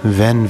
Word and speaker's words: Venn: But if it were a German name, it would be pronounced Fenn.